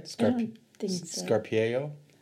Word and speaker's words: scarpiello: Sure what L'Avignon is for, but they what George and Mary suggested is to pair it with a chicken scarpiello. Did I say that right? – Scarpiello.